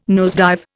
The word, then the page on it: nosedive: Indian